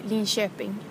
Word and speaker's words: Linköping: Is that how you say it? – We say Linköping.